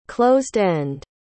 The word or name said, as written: closed-end